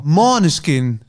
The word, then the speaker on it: Måneskin: Måneskin. Ja, een beetje op zijn boers moet je het uitspreken.